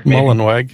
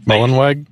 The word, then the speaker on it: Mullenweg: You know, like if you go onto the, you know, the WordPress, like the automatic site, you know, is Matt, I'm going to butcher his name here, Mullenberg? – Mullenweg. – Mullenweg. Like, Mullenweg, yeah.